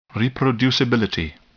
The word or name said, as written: reproducibility